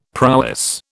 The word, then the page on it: prowess: British English